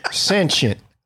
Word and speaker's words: sentient: Unless you say sentient. – Yeah. – Sentient. Put the emphasis on the first syllable.